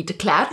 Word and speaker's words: de Klerk: Well, my married name is Michelle de Klerk, which I think in South Africa would be de Klerk. – Yeah, de Klerk. – De Klerk. But if you say it like that here, nobody knows what you're talking about.